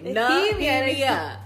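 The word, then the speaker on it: Nehemia: Nahemia. Why you can't ever remember her name? – I swear. Dude, I always I'm getting offended. Remember the black character's name? And pronounce her name correctly. Nahemia.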